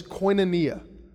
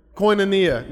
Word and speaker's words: koinonia: Uh, this is this word for fellowship or partnership, and it's, and it's pronounced like this koinonia. Everybody said that with me koinonia.